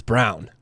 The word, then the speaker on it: Braun: So what do you think about Braun? Are you saying Brown or Braun? – I believe it's Brown. It's spelled like Braun, I agree.